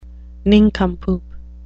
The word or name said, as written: nincompoop